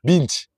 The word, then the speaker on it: binge: Binge eating. Bin? Binge? Binge. Binç. Abi. B-I-N-C-H. H değil. B-I-N-G-E. Binç. Ha binç.